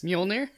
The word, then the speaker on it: Mjolnir: I love these movies, like the Thor movies, if only because Mjolnir.